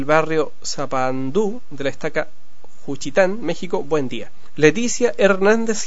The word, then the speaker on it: Juchitán: Mariana Vicenta Gil, de, aquí tengo que tener cuidado con la pronunciación, dice Juchitán de Zaragoza, Oaxaca, México. Es Juchitán, eso es, de Zaragoza, Oaxaca, México.